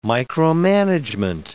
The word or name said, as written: micromanagement